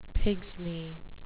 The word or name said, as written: pigsney